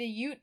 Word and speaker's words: Ute: So the relationship between these two tribes went, I wrote, hella downhill during the Civil War, when the Oot, I really should have looked up how to pronounce this, the Ute tribe allied with a U.S.